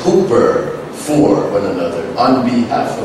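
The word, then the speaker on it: hooper (ὑπέρ): Now, according to Strong's Greek lexicon, that Greek word hooper up there, pronounced hooper, means over with the object in the genitive case.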